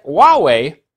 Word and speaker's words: Huawei: Huawei, which is spelled H-U-A-W-E-I, but it's Chinese, so it's pronounced Huawei.